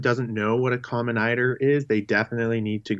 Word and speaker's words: eider: Now, your research is the population genomics of an Arctic seabird, the majestic common eider. Is that how you say it? As an eider sea duck? – Yeah, exactly. Yeah.